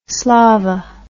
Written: slava

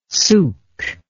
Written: suk

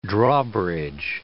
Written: drawbridge

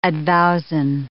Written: advowson

